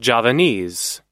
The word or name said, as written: javanese